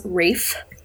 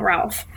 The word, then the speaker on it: Ralph: Also, his name is pronounced Rafe. – It is. – Yes, it's not Ralph.